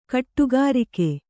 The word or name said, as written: ಗುಡಿಗಾರಿಕೆ